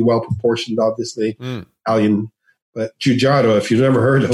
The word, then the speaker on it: Giugiaro: So, Giugiaro. Okay, you gotta say it like that. – Giugiaro.